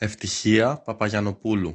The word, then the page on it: Evtychia Papagiannopoulou: griechischen; Muttersprachler aus Athen